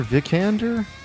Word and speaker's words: Vikander: Vikander.